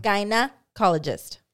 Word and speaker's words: gynecologist: Gyna. Like vagina. – Uh-huh. – Gynecologist. There you go.